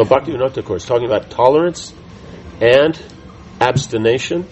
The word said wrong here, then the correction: abstinence: Is that how you say it? – Abstinence.